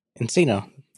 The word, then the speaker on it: nCino: I don't know how you pronounce this. It's just an N-C-I-N-O. – Encino.